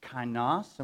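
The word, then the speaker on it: Kainos (καινός): But this whole series came about when Fred brought to our attention the word, I believe it's pronounced kainos. Am I pronouncing? Kainos. Yes, and it means new.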